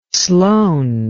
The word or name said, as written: sloane